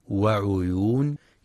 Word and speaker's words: وعيون: وفي قوله سبحانه إن المتقين في ظلال وعيون في كلمة وعيون ينتبه القارئ برواية حفص لضم العين فلا يقرأ بكسر العين وعيون.